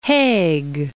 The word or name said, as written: Haig